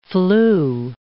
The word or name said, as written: flue